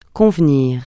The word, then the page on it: convenir: francais